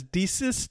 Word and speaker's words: deesis: First is deesis, D-E-E-S-I-S would be how you pronounce it, which is a request, a supplication, right?